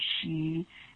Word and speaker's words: sidhe: The Irish they call them a fairy folk or she, S I D H E is spelled in Irish.